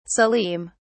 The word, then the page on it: salim: British English